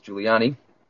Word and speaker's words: Giuliani: But you can get those by writing to me, Charles Giuliani. That's spelled G-I-U-L-I-A-N-I. Yes, just like the former New York mayor. Again, that's G-I-U-L-I-A-N-I.